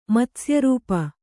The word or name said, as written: ಮತ್ಸ್ಯರೂಪ